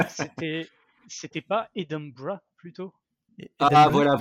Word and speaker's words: Edinburgh: Écossais disent Edinburgh. – Edinburgh.